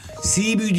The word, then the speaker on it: Cebu: Cebu diye yazılır mı? Sibiu diye telaffuz edilir.